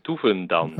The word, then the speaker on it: Doevendans: How would you say that in Dutch? – Okay, I will. The first name pronunciation was perfect. – Then. All right, I'll take it. – And the surname is Doevendans.